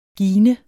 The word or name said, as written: gine